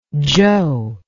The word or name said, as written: zhou